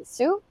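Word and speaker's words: Hsu: I really like Stephanie Sue. That's how you pronounce her name.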